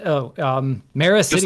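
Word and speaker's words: Meracydia: You said something about Mercadia. Can you explain that? – The southern continent. Maricidia, like, is the way we pronounce it.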